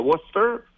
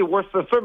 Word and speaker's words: Worcester: J'ai commencé avec le club école qui était à Worcester. – Oui. – Ça s'écrit Worcester, mais il faut dire Worcester. Welcome to Worcester.